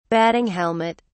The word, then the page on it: batting helmet: British English